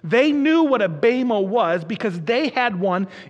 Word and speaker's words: bema: The Greek word that he uses here for judgment seat, perhaps you've heard it before, is the word bema, B-E-M-A, pronounced bema.